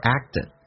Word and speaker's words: act: 对一个刚在一起第一次那么约会的一位女士，来看一下 have you 不被强调，第一个 have 说成 her， you 可以说成也，也可以说成 you，用降调来发 have you， have you ever， have you ever acted，这个单词听力有点难点，第一个是那个可你听不到，每次发音那个可不发音，两个辅音在一起就用发后面一个，而且气流要阻塞一下，叫 act，而且 t 要发成 d 浊化 act